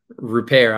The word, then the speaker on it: Rupert: Rupert. I always remember it by saying that the jump shot is not beyond repair.